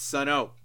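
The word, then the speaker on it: Sunn O))): It's pronounced Sun. No, they have the O there. It's Sun O, bastards.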